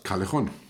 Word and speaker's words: Callejon: So, wir haben jetzt mal kurz eine Pause eingelegt, damit ich in die ähm, äh, Calochon, spricht man dazu aus? Calejon.